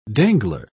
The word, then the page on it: dangler: British English